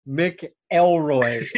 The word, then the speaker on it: McElroy: Yeah, it's pronounced McElroy. – Then why is it MCC? You need double C for it to be a Mac. – No, you definitely don't. I think I guess it would be like MAC, but that's just the way it's pronounced.